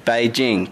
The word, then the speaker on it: Beijing: Beijing. – Beijing. Now, this is annoying me. A lot of people are saying Beijing. It's like two separate words, as in bay, like where you park your boat, and Jing, as in the name of...